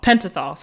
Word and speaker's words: Pentothal: But the police did use sodium pentothenol. I don't know if I'm saying that right. It's truth serum. Am I saying that wrong? – No, I don't know. Like what? – Pentothal. Sorry, sodium pentothal.